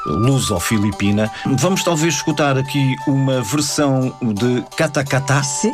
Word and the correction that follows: Katakataka: Katakataka. Isso. – Falta uma sílaba. – Sim. – Original filipina, mas pelo coro português de Câmara de Lisboa. – É uma peça viva. Katakataka.